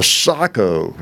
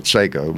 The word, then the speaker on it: Sako: The last one you'd sell, I kept the Sako, is how you pronounce it, but everybody calls it Seiko, but I think it's Sako, that Bavarian.